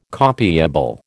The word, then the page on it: copiable: American English